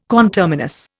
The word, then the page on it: conterminous: Indian